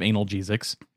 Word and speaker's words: analgesic: Speaking of analgesics. Analgesics in space. – Going no, to Uranus. – Sir. No, sir. That's pronounced analgesic.